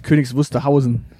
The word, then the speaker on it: Königs Wusterhausen: Ich freue mich auch schon die, äh, auf die Adelssendung im wöchentlichen Wechsel aus Königs Wusterhusen und Fürstenwalde. Äh, du meinst Königs Wusterhausen.